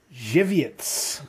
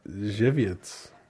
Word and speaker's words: Żywiec: This is, now I have a pronunciation guide for this, Żywiec. – Żywiec. – Z-Y-W-I-E-C.